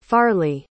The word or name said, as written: farley